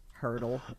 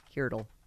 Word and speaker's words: Heardle: Hurdle. And it's spelled Hearle, right? Not like a jump. – Like here. Heardle. – Heardle.